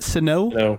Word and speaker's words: Sinnoh: Sinnoh.